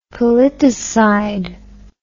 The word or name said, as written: politicide